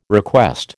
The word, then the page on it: request: American English